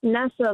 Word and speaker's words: Nessa: Is it Nisa or Nessa? – Nessa, like Vanessa without the V.